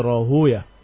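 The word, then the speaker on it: Rohuya (راهويه): Wahakada yukra. Walaupun sebagian membaca Rohawai seperti Sibawai, seperti Naftawai, tapi sebagian mereka membacanya bukan Rohawai tetapi Rohuya.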